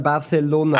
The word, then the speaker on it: Barcelona: Nee, und, äh, Barcelona äh, spricht man Barcelona. – Ah, okay, okay, Barcelona.